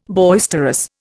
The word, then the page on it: boisterous: Indian